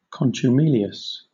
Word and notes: contumelious: British English

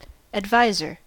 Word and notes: adviser: American English

